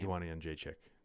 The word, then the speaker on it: Jędrzejczyk: I thought we were going to see a second Joanna Jurkic. I can't say her name. – Joanna Jędrzejczyk. – Thank you.